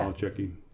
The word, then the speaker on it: Nolichucky: I went up to the Nolichucky River. Is that how you say it? – Yeah. – Nolichucky, from Tennessee down into North Carolina.